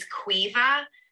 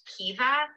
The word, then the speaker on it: Caoimhe: It's pronounced differently depending on where in Ireland you are. And where I am, it's Cuiva, but I know in other places in Ireland, Cuiva. It's pronounced Kiva. All the Cuivas I know are Cuivas. Cuiva is spelled C-A-O-I-M-H-E.